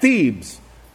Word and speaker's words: Thebes: It says the earliest known, now get this, the earliest known palaces were the royal residences of the Egyptian pharaohs at Thebes. That's the name of the town, T-H-E-B-E-S. Put that right there for you, Thebes. And that's how it's pronounced, Thebes.